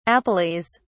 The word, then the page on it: appellees: British English